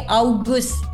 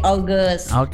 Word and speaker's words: August: Nah, kalau bahasa Inggrisnya agak mirip tuh juga huruf-hurufnya, tapi August. A-U-G-U-S-T kan, kalau bahasa Inggris jadi August. – Bacanya sedikit pakai O, berarti August gitu ya.